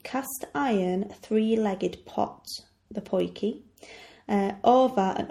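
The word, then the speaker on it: Potjie: And the thing I'm going to talk about is the poiki. Poiki, which is spelt P-O-T-J-I-E, T-J-I-E makes the sound key, is short for Potjiekos, which means small pot food in Afrikaans.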